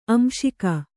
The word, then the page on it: ಆಂಶಿಕ: āmśika